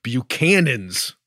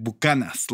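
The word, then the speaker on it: Buchanan's: Ah, that would be the fabled Buchanans. – Yeah, but we pronounce it Buchanas.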